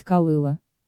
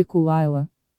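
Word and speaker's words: Caol Ila: Примером может служить Каолыла, который рекомендуется произносить Калыла, или Кал-Айла, или Кулыла, или Кулайла. В таких случаях принималось, как обычно говорится, вкусовое решение, в частности, из указанных транскрипций выбрано последнее.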